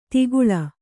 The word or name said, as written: ತಿಗುಳ